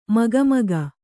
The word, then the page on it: ಮುಖಾಮುಖಿ: mukhāmukhi